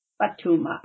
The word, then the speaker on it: Fathuma: So my name is Fathuma with a U. – Fathuma.